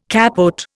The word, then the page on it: capote: Indian